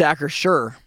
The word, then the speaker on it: Acrisure: It's Acrisure. – It is not Acrisure. I heard an ad yesterday that was basically the company itself saying Acrisure. It's not Acrisure.